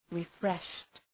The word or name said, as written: refreshed